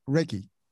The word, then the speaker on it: Reiki: Now you mentioned Reiki, or yes. – is that how it's said? – Yes, yes.